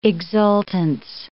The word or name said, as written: exultance